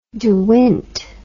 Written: dewint

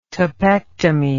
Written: topectomy

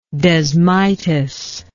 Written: desmitis

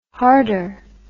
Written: harder

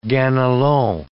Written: Ganelon

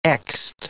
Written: xed

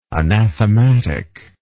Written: anathematic

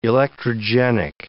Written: electrogenic

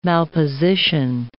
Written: malposition

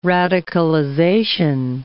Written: radicalization

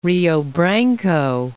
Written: Rio Branco

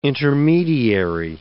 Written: intermediary

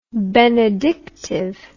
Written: benedictive

